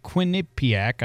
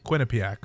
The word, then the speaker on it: Quinnipiac: The Quinnipiac, I always butcher this. How do you say it? – Quinnipiac.